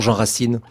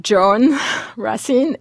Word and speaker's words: Jean Racine: Et comment prononce-t-on, quand on est une jeune Américaine ou un jeune Américain, comment prononce-t-on Jean Racine ? – Alors, quand c'est pas John Racine et Peter Corneille, ils font l'effort de nasaliser, donc John, Jean, enfin après ils y arrivent, mais c'est vrai que ça demande beaucoup beaucoup d'efforts.